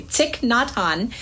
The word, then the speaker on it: Thich Nhat Hanh: I looked it up so I would pronounce it correctly. Thich Nhat Hanh.